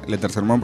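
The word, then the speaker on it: La Tercer Monde: Sí, y también tenemos otras vías de comunicación como son las redes sociales, estamos en Facebook, somos La Tercer Mon, que se pronuncia La Tercer Mon, pero se escribe La Tercer Mon D. – Con E final.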